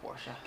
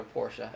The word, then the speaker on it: Porsche: I want to get a Porsche. – That's what I thought it was. I love Porsches, man. – I want to get a Porsche.